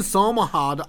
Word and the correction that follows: Somerhalder: I don't know how to fucking pronounce that name, who plays Damon in The Vampire Diaries, is married to Nikki Reed, who plays Rosalie in Twilight. – Somerhalder? – Is Rosalie in Twilight the hot one?